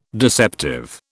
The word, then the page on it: deceptive: British English